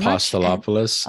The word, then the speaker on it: Apostolopoulos: So once again, Dr. Apostolopoulos, thank you very Apostolopoulos.